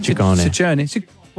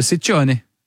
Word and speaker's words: Ciccone: Well, in Italian, it works the other way. So the double C's are hard C's and the singular C's are soft C's. – So Ciccone. – Ciccone.